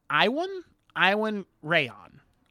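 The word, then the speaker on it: Iwan Rheon: They're in their flat, and there's a knock at the door, and it is Ash, who is played by Iwan Rayon, I believe is how you say it.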